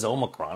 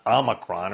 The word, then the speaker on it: Omicron: And by the way, that is how it's pronounced. It is Omicron. It's not Omicron or anything else.